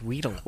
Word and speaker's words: Weedle: A pants to me, and I'm like, how do you say this Pokemon's name? What? – Weddle? Weddle? No, it's Weedle.